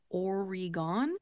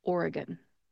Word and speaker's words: Oregon: The E in the middle of the word is a schwa type vowel sound because it is the unemphasized syllable. So when you pronounce that, make sure to take that E and turn it into a schwa sound. When you pronounce it, it therefore becomes Oregon. Oregon.